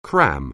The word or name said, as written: cram